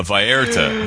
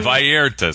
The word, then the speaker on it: Vallarta: A Vallarta. Vallarta, Scott.